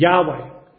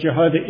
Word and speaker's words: Jehovah: Now, in recent times, scholars have proposed that the correct pronunciation of this name is Yahweh, which is constructed using the vowels of Adonai in the tetragrammaton. However, there is greater evidence to show that Jehovah is the correct pronunciation based on the Masoretic Hebrew text, which we believe is a preserved Hebrew text.